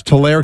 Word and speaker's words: Tulare: Is it Tulare or Tulare, California? – Oh, there it is. – Tulare, California. You see that? – I do. – Tulare. It's Tulare, California.